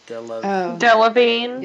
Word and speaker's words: Delevingne: Cara Delevingne. – Yeah. Delevingne.